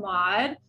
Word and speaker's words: Maude: Another one is called Mod. It's sexual wellness for everyone. So it's actually a unisex brand, and it's spelled M-A-U-D-E.